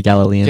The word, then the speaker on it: Galilean: Yeah, Galilean.